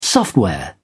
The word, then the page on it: software: British English